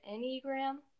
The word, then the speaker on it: Enneagram: Is it Enneagram? – Yeah, Enneagram.